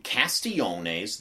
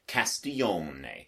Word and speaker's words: Castiglione: And I need to thank MG for giving me that Italian pronunciation a few years ago, Castiglione. Now, you can say, it's a written test, if it makes you feel better to say Castiglione, then by all means, Castiglione.